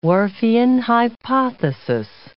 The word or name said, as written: Whorfian hypothesis